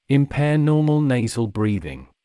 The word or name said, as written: impair normal nasal breathing